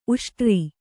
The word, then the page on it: ಉಷ್ಟ್ರ: uṣṭra